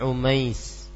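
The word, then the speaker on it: Umais (عميس): Beliau adalah Asma bintu Umais. Asma, penyebutannya Asma'u bintu Umais. Tulisannya Ain Mim Ya Sin, dibacanya Umais.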